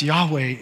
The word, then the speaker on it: Yahweh: So all modern denominations of Judaism teach that this four-letter name of God, YHWH, which we would pronounce Yahweh, is forbidden to be uttered except by the high priest in the temple.